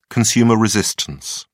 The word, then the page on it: consumer resistance: British English